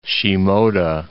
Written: Shimoda

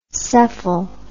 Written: cephal

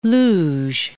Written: luge

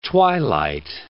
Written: twilight

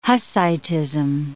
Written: Hussitism

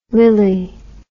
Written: lilly

